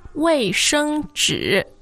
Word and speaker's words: 卫生纸: 卫生纸. – 卫生纸.